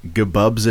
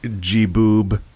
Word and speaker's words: Gebub: So I talked about this last week, but because no one heard it, I played a game called Gbub's Adventure... or possibly G-bub. – Or G-dot bub. – Like G-boob. It's spelled G-E-B-U-B.